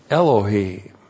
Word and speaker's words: Elohim: Elohim. Aleph, E-L-O-H, long I-M, Elohim, God.